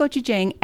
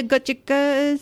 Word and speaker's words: gochujang: The very helpful clerk didn't make me feel bad for mispronouncing the word. I was just like, do you have some of the gochuga stuff?